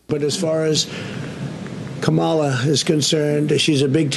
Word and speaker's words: Kamala: You're not allowed to criticize Kamala Harris or Kamala Harris or whatever.